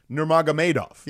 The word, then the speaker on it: Nurmagomedov: There are a lot of people that never try to say his last name, and it's Nurmagomedov. Yeah, how you say it, Nurmagomedov.